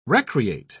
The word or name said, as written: recreate